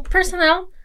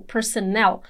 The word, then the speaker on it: personnel: So personnel has that important stress in the last syllable, personnel.